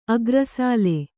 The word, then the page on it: ಆಖೈರುಸಾಲು: ākhairusālu